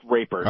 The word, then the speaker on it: Rapert: Is that how it's pronounced? Or is it Raypert? – I've only heard it pronounced Raypert.